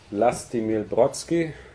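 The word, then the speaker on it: Vlastimil Brodský: So you see, I don't know whether I pronounce it right, Lastimil Brodsky. So how? – Yes, you are pronouncing it.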